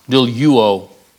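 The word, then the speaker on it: douluo (δουλεύω): Of doulos, douluo, it would be pronounced.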